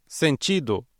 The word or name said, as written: sentido